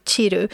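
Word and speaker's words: chiru (散る): Um, hur uttalar man det på japanska? Barawa utsukushiku shiru. – Ja, chiru. – Uh, barawa utsukushiku chiru.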